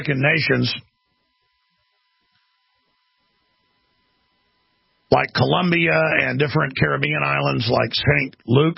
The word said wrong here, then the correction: St. Lucia: St. Lucia.